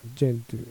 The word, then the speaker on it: Gentoo: それじゃ以外には一風変わった Gentoo ゲントと読むのか、ジェントゥって読むんですよ。ジェントゥ、皇帝ペンギン、ペンギンの、まあ、名前ですけど、ジェントゥペンギンって言いまして、で、ここの団体のオペレーティングシステムっていうのが、めちゃめちゃ優秀であると。で、僕は自分の持ってる Mac に、そのジェントゥ